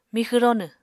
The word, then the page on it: mixw ronw: Apatani